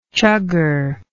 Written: chugger

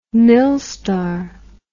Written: milstar

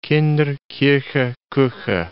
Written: Kinder, Kirche, Kuche

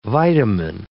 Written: vitamin

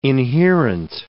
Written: inherent

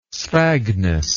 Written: sphagnous

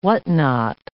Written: whatnot

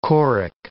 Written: choric

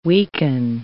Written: weaken

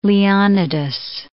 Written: Leonidas

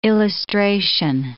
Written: illustration

